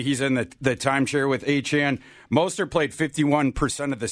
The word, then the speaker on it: Achane: I'm going to give both Mostert and Achane, as we've learned, is the pronunciation.